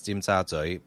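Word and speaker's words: Tsim Sha Tsui: I mean, in Cantonese, it's tim sa tsui, but then I don't know how to romanize that. I mean, tim sa tsui, tim sa tui.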